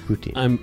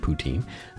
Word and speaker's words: Poutine: After a month of listening to you guys basically every day, I'm now all caught up on Dark Poutine. – Oh, wow. – And looking forward to new episodes. See how I'm pronouncing it correctly? – Yeah, Poutine. – Poutine.